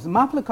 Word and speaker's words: Mapla: Mapla Comics, spelled like maple, but actually pronounced Mapla, like, as in the Tamil word for bridegroom.